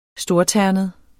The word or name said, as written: STORTERNET